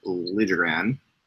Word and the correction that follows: Liljegren: To a 3-year entry-level contract, but also it's been clarified his name is pronounced Liljegren. – Liljegren.